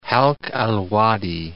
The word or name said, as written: Halq al-Wadi